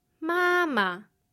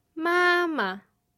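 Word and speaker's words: Mama (妈妈): The same pattern holds true for mommy. – Mama. Mama. – The first syllable is a first tone, which is a high-level tone. The second syllable is that same word, but with no tone at all.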